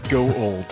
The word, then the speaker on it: Gould: Is it pronounced Gowald or Gould? it's, it's go old.